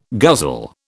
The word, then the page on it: guzzle: British English